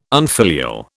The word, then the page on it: unfilial: British English